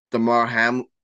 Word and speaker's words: Damar Hamlin: But obviously, if you've been living under a rock, Damar Hamlin, is how you pronounce it, Alex? – Yeah, that's how you pronounce it.